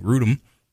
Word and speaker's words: Grudem: So I am in with Bob Grumman, and we have been talking about yesterday's program with Wayne Grudem. I said it right. I didn't say Grudem.